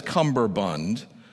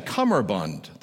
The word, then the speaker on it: cummerbund: Well, he pronounced it as cummerbund, but it's actually cummerbund. There is no B.